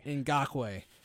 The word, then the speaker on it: Ngakoue: Ngakoue.